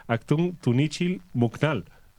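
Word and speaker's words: Actun Tunichil Muknal: pero esta es la cueva de Aktung Tunichil Muknal.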